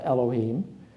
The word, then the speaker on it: Elohim: It's pronounced Elohim.